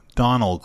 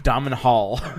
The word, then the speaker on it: Domhnall: Donald Gleeson. – No, I don't respect him enough to say his name correctly. – Domhnall.